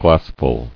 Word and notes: Glassful: glass·ful

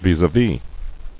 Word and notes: Vis-a-vis: vis-à-vis

